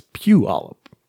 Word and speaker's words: Puyallup: I mean, yeah, but you kind of say it with the Y before the U, even though it's spelled with the U before the Y. Because it's not Puyallup, it's Puyallup.